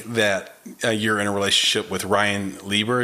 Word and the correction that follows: LeBar: Is that correct? – LeBar. Yeah. – LeBar. Ooh, thank you for correcting my pronunciation.